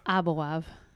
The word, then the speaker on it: Aboav: I said it right, didn't I? It's Aboav or is it Aboav? – Aboav. – Okay, my bad.